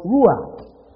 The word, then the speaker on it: ruach: the word ruach. R-U, some spell it W-A-C-K, and some spell it with another W. Ruach.